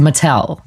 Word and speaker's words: Mattel: So you should say it like an American, correct? – Yes. – So Mattel.